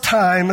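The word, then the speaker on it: time (τιμή): The Greek word for honor is spelled T-I-M-E. Right? It's not pronounced time, it's pronounced time. Right? But the Greek word for honor, T-I-M-E.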